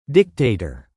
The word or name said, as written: dictator